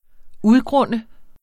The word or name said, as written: udgrunde